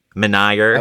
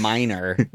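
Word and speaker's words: Menier: Meunier. – I would have said the Meunier Chocolate Factory. – I probably would have said Minor, which is even totally wrong. That's not even how it's spelled.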